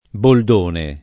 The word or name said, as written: boldone